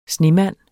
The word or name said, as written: snemand